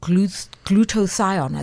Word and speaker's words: glutathione: I've seen many people many so-called A-list celebrities in South Africa going for glutathione. Is that how you say it? – Yes.